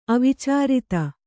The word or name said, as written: ಅವಿಚಾರಿತ